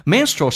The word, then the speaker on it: menstrual: Menstrual cycle 指的是生理期。那这个单词真正的发音叫 menstrual, ual, 但是我们发现 ual 组合在一起的时候经常可以把 u 去掉，比如说 usually, 我们经常说成 usually, actually, sure, 这个单词 sure, OK, 所以你要知道 ual 的组合经常可以把 u 拿掉，因为偷懒。